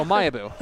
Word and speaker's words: Omayebu: Yeah, I'm putting the wrong the emphasis. syllables, I'm putting yeah. Omayebu.